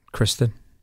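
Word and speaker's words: Kirstjen: It's a silent J. – All right. So how do you say it? Kristen? – I think so.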